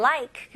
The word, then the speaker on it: like: 네, 우리나라 사람들은 like라고 외래어로 많이 사용하잖아요. 하지만 영어식으로 발음하실 거면 like가 아니고요. 이름절로 like 해볼게요. Like.